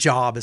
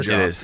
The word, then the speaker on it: Job: Speaking of how well I was doing, I was reading in Job, and it actually— I think it's Job is how it's pronounced. – It could be Job.